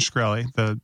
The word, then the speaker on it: Shkreli: how is it when it's, So the benefactor was Martin Shkreli, the diabolical.